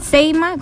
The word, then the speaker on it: CEIMA: Seima, con C, seima, arroba... ¿Cómo se escribe Seima? ¿Ese es Seima? – C-E-I-M-A.